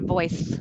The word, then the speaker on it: voice: So the boys is almost more of a z sound. Boys and voice. It's more of an S sound at the end.